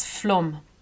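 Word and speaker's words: Flåm: And then as I said, I was going out to see this fjord in an area known as Flum, actually spelt F-L-A-M, but it was part of this gigantic fjord with which meets the ocean, called Sonja's Fjord.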